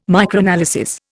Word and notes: Microanalysis: Indian

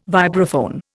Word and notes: vibraphone: Indian

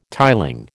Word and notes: tiling: American English

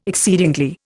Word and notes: exceedingly: Indian

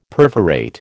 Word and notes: perforate: American English

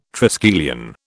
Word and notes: triskelion: British English

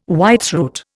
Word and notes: whitethroat: Indian

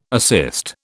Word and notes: assist: British English

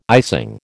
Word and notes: icing: American English

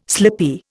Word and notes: slippy: Indian

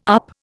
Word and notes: up: Indian

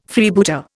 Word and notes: Freebooter: Indian

